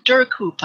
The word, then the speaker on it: Duhrkoop: Dan Durkoop, I think, is how you would pronounce it. D-U-H-R-K-O-O-P.